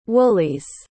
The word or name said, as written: woolies